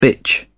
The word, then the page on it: bitch: British English